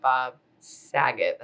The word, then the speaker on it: Bob Saget: no, exactly but with the recent news and the tragic passing of bob saget that's how you say his name right yeah I, I always want to say Bob Saget.